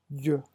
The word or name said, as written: j